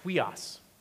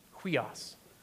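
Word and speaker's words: huios (υἱός): That, that Greek word for, for child and children there is, is pronounced huios, huios.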